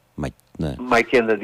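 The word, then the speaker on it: Macheda: Ματσέντα, δεν ξέρω πώς προφέρεται, Ματσέντα TV. – Μακέντα TV.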